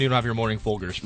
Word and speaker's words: Folgers: Folgers. – Yeah, you know, not quite. But is that how you say it? – No. – How do you say it? – Folgers.